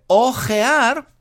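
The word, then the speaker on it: hojear: Eh, ojear, que se pronuncia igual, pero se escribe con H, ojear con H, viene mm, de hoja, de hoja, por ejemplo, las hojas de un libro.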